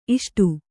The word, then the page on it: ಇಷ್ಟೆ: iṣṭe